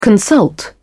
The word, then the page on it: consult: British English